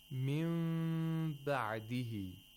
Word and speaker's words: min ba'dihi: We have iqlab of nun sakina here. The nun sakina should be pronounced like a mim which has ikhfa. Min ba'dihi. And the ayn has to be pronounced with tawassul.